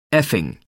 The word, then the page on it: effing: American English